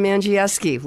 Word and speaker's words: Andrzejewski: Well, in Polish, they say Andrzejewski but we've tried to help out over the years. My family pronounces it Angieski, just to make it a little easier.